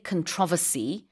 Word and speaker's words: controversy: 명사 형태로는 발음이 두 가지인데요. I say controversy, and I think you say it differently.